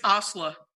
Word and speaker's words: Asli: First name uh, A S L I. – It's Osla.